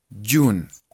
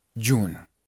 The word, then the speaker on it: June: Entonces, esta palabra se pronuncia primero como si la J fuera una Y y como si no tuviera la E. Jun. así nomás. Jun.